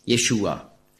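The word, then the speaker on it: Jeschua: Jeshua war ein Problem für die Griechen, denn im griechischen Alphabet gibt es keinen Sch-Laut, sondern es gibt nur das Sigma, S. Also konnte man nicht sagen Jeshua, sondern höchstens Jesua.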